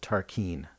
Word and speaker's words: Tarkine: I think it's pronounced Tarkin. It sounds like Rob says Tarkin to me.